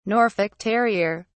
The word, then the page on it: norfolk terrier: British English